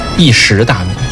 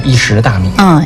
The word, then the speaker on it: 石: 注意发音，一石大米。 – 干，哎，扣钱啊，监听老师肯定扣钱。 – 哎，一石大米。